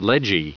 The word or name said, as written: ledgy